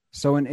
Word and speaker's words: Samhain: It's it's Gaelic, but I know it's actually spelled S A M H A I N. – Okay. – It, like the Celtic words are never pronounced like they're spelled.